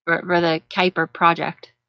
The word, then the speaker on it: Kuiper: Like the Cooper belt. We have a belt to stuff. – Kuiper. Okay, so it might be pronounced Kuiper. Well, that would make sense. If it's pronounced Kuiper, like the Kuiper belt, then it would make sense.